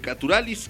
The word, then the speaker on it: Caturalis: Caturalis, sí, de hecho te pedí que me lo anotaras al principio como se escribe, y es Caturalis, como naturales, pero en lugar de la N la K y en lugar de la E la I.